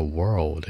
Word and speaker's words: world: 注意，世界这个词的发音叫 world，有个 l 的音，舌头卷起来，world。